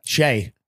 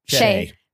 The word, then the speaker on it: Shai: Okay, I don't know how to pronounce his name. Shai Gilgeous-Alexander? – Mm-hmm. Yeah. Shai. – Shai.